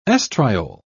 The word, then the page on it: estriol: British English